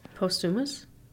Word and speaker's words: posthumous: Hom- that's a hard word to pronounce. – Posthumous. – I don't think it's humus. that's a thing you put on on bread in Israel. Shut up. I think we've looked this up before on the podcast. I think we've done this exact thing. Can I have a Google search? Post hominis. Uh, give me a pronunciation, please. – Posthumous. – Posthumous. – posthumous. I was close.